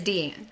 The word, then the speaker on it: Deanne: There is a Leslie Dean out there, like a true Dean. So Leslie Deanne is my name. I've literally dealt with that my whole life, so it doesn't bother me. It's just when people say my name, it is Deanne.